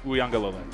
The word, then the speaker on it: Uiagalelei: The official pronunciation is Lele that I have read. Uiagalelei.